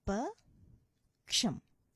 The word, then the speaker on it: పక్షం: If you look at this word, you can break it down into two or three syllables, but the apt way of breaking it down would be pa and ksham. Okay, so pa ksham, paksham. So when you put it together, it sounds like this: paksham, paksham. The ksha in this particular word is a compound letter which incorporates ka and sha vattu antamu, so it becomes ksha, paksham. All right, that is how you pronounce this word.